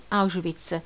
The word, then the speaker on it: Auschwitz: Finisce così la nostra breve puntata a Osviecim, che che i nazisti tradussero in Auschwitz con l'accento non scritto ma duro e puntato sulla A, con un risultato onomatopeico che dà perfettamente l'idea di una maledizione e una frustata in un sol colpo.